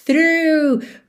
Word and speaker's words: through: Acho que não tem mistério nenhum, só essa palavra through, para quem tá lendo, às vezes parece muita informação. T-H-R-O-U-G-H. Mas a pronúncia é simples. Through, through, ok?